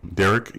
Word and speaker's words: Dereck: I can also be found on all social media platforms under my full name, Derek E. Moore. And Derek is spelled D-E-R-E-C-K.